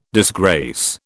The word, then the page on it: disgrace: British English